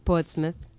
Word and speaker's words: Portsmouth: Vengo de Portsmouth. Que se pronuncia Portsmouth en Inglaterra.